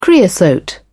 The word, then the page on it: creosote: British English